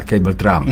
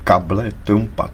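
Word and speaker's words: cable trampa: Ne, ne, ne, to se vyslovuje kable Trumpa. To je španělský termín značící nástražní drát k mině. – Aha. – Čili past, nástražnou past, kable Trumpa ve španělštině.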